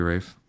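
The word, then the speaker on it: Ralph: It's actually pronounced Rafe.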